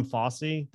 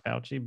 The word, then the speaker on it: Fauci: I don't know if I should admit that or not, but actually we called him Fossey. That's the way we pronounced it as kids, not Fauci, but yeah.